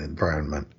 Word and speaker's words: environment: And I think there are a lot of people that just aren't aware that that first N is there in environment. I put in a reminder, if you know the related word environs, it may help remind you, but I'm afraid that the people who misspell it probably don't know the word environs either. – Mm-hmm. Yeah. When you drop the N entirely, it's not necessarily going to set many people's teeth on edge, I wouldn't think.